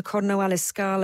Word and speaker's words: Corno alle Scale: stage 9 to Corno alle Scale.